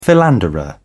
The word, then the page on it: philanderer: British English